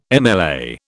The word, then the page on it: MLA: British English